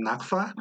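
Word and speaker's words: nakfa: It's called the naphtha. – Ding, ding, ding, ding. Wait, say that again. – Naphtha. – Man, I would have pronounced that so wrong.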